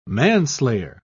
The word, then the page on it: manslayer: British English